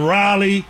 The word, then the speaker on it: Raleigh: And then Raleigh.